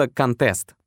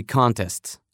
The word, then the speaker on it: contest: She's won a lot of beauty contests. Она стала победительницей во многих конкурсах красоты. Сместим ударение на второй слог и получим глагол contest – оспаривать, опротестовывать, бороться, отстаивать.